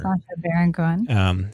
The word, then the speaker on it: Sacha Baron Cohen: Sacha Baron Cohen's character. – Sacha Baron Cohen.